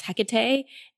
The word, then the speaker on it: Hecate: You actually pronounce Hecate's name perfectly, okay, good. – Which is very unique. It is Hecate.